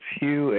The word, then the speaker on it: Hugh: Yeah, so if you just put my name on Twitter, it's Hugh, H-U-G-H, last name Trawlson is spelled T like Tom, R-A-U-L-S-E-N like Nancy, and then Twitter, T-W-I-T-T-E-R after my name.